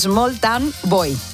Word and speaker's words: Small Town Boy: El primero fue ese maravilloso Small Town Bow. Small Town Bow. Hoy voy, hoy se lo digo bien. – A ver, ¿cómo es? – Small Town Boy.